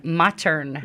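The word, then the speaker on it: Mottern: And that's M-O-T-T-E-R-N, Modern. – Yes, it's pronounced Modern with a D, but it's just spelled different.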